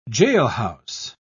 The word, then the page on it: jailhouse: British English